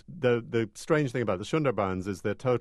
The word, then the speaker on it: Sundarbans: And I'm thinking of your trip through, am I pronouncing it right, the Sundarbans? – Yeah, the Sundarbans, the coastal forest.